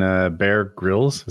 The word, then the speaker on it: Bear Grylls: I've seen Bear Grylls.